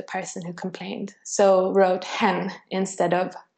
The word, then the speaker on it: hen: Which is hen, H-E-N. – Which is hen. Yeah, exactly. Pronounced like the bird in English.